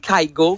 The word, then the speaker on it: Kygo: Kygo, c'est comme ça qu'on le prononce? – OK, le DJ Kygo.